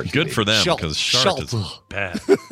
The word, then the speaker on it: Chartres: AB is the first one, I think, to actually give a real answer to how to pronounce that city in France, and it's Chartres.